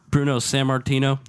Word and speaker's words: Bruno Sammartino: Bruno Sammartino.